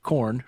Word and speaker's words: QORRN: I'm going to spell that out, Q-O-R-R-N, and yes, it is pronounced corn for short.